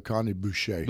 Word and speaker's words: Boucher: Yeah, so C. Boucher. Did I pronounce that right? – No, Connie Boucher. – Boucher. See, that's an East Texan trying to pronounce a French accent.